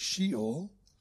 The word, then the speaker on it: Sheol: They are, in Hebrew, Sheol, or usually we pronounce it Sheol, and in Greek, Hades, or Hades.